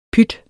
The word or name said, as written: PYTTENS